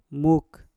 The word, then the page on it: muk: Mpur